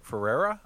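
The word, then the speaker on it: Ferrera: how do you say it? America Fiera? – Ferreira? I thought it was Ferreira. – America Ferreira? Sure.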